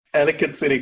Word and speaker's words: Ellicott City: Ellicott City, Columbia.